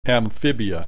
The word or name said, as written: Amphibia